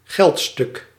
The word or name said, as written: monnaie